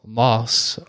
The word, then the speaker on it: Maus: I was telling you earlier, this kind of reminded me of the graphic novel Moss, which is spelled M-A-U-S.